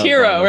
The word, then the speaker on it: Herro: I always say his last name wrong. I don't know why. It's Herro, right? – Yeah, Tyler Herro.